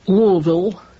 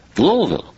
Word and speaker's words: Louisville: I was at a convention in Louisville. – Louisville? – Louisville. Learned how to pronounce it very quickly.